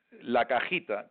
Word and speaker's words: cajita: La cajita.